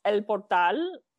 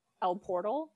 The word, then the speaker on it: El Portal: In the fall of 1997, Carrie drove his international scout to a tiny town in Spanish, it's El Portal, but they kept calling it El Portal. So I'll call it El Portal.